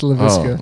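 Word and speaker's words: Laviska: Is it LaViscus? – It rhymes with biscuit. – It's LaViscus.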